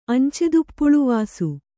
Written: ಅಂಚೆದುಪ್ಪುಳುವಾಸು

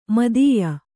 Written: ಮಿಥ್ಯ

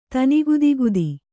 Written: ತನಿಗುದಿಗುದಿ

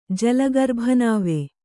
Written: ಜಲಗರ್ಭನಾವೆ

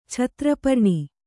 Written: ಛತ್ರಪರ್ಣಿ

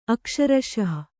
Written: ಅಕ್ಷರಶಃ

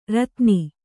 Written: ರೋದನಿ

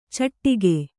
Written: ಚಿಟಿಕೆ

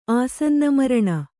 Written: ಆಸನ್ನಮರಣ

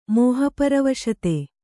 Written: ಮೋಹಪರವಶತೆ

